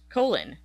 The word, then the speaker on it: Colin: Who is a very big figure, but it's spelled C-O-L-I-N. It's spelled like Colin. – Right. – But it's pronounced Colon.